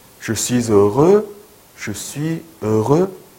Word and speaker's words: heureux: 행복한 이라는 뜻의 형용사 heureux, heureux, heureux 발음 좀 어렵게 느껴질 수 있는데요. 입 모양이 중요해요. heureux, heureux. 입 모양 바꾸지 않고요. heureux, 네, heureux 이거예요.